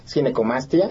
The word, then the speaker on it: ginecomastia: Ginecomastia.